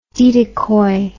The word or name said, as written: didicoy